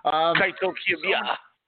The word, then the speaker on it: Kaito Kiyomiya: Kaito Kiyomiya.